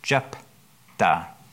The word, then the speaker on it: Jephthah: Jephthah. I think that's how it's pronounced. Yeah, Jephthah? – Jephthah. – Okay.